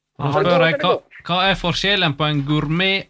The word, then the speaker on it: gourmet: Gourmet, eller jag vet inte hur det uttalas lite här. – Gourmet. – Lite som fransé, ja.